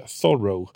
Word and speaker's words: Thoreau: Jag hade ju som Thoreau. – Mm. – Jag försöker vänja mig att han var amerikan och inte fransman, så det uttalet där.